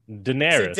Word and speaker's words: Daenerys: No, how do you say Daenerys? – Daenerys.